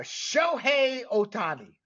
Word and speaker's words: Shohei Ohtani: We're also going to mention, and we're finally going to get into some Major League Baseball, Shohei Ohtani. Is that correct there, Mr. Aparicio? – That is it. That's how you say it.